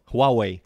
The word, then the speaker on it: Huawei: Hay una compañía china de eh, celulares que se pronuncia Huawei.